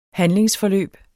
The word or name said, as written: handlingsforløb